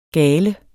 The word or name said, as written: gale